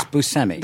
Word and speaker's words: Buscemi: You said it the way I say it. – Yeah. – Buscemi.